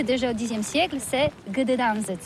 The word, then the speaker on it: Gdańsk: Alors d'abord, comment prononce-t-on bien ? Ça s'écrit G-D-A-N-S-K. On dit Gdansk ou Gdansk, on prononce le G ou pas?